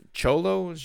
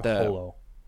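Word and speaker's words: Xolo: I believe Holo. – Holo? Okay, I heard in a review they called him Cholo. – I could be 1,000% wrong on this, but I believe X in Spanish is H.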